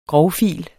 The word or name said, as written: grovfile